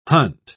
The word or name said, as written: hunt